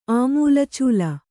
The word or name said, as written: ಆಮೂಲಚೂಲ